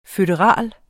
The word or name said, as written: føderal